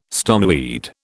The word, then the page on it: stoneweed: British English